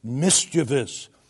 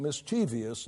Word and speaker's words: mischievous: The only result of his solitary practice is therefore mischievous and brethren, the word is not mischievous. Don't put, pronounce all your syllables, but don't put extra ones in there. And when I hear men who ought to know better talk about mischievous, no, it's mischievous. That's the proper word, the proper pronunciation.